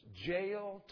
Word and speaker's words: Jael: It rhymes. Jael took a nail.